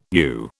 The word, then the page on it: ewe: British English